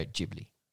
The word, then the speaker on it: Ghibli: It shall now be always pronounced Studio Ghibli.